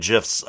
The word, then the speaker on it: GIF: By the way, friends, Jimmy G is pronouncing GIFs correctly. The creator of that graphic format has said it is pronounced GIF.